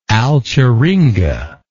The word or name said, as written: alcheringa